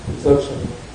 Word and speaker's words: Dzogchen: It's about, I don't know how to pronounce it, Dzogchen? – Dzogchen.